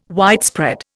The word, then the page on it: widespread: Indian